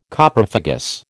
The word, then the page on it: Coprophagous: American English